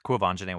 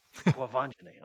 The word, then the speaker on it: Quvenzhané: Quvenzhané Wallace. – Quvenzhané, okay, yes.